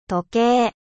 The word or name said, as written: horloge